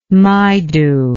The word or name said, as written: Maidu